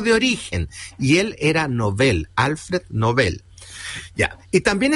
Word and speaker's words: Nobel: Ambas palabras son agudas, por lo tanto, su pronunciación adecuada es Nobel.